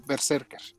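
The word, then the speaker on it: BRZRKR: ya, ya dijeron porque ya ven que el título era BZ y tomo, y eso cómo se pronuncia, ya dijeron que se pronuncia Berserker.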